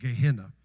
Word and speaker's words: Gehenna: Jesus actually 12 different times, some translations have more than 12 times, references hell as Gehenna. Sometimes it's pronounced Gehenna. Gehenna or Gehenna.